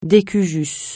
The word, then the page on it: de cujus: francais